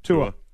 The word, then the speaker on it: Tua: We just call him Tua. – Tua.